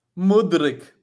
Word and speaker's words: Mudrick: Mudrick. Like, it's almost like Modric. It's almost like Modric Mudrick.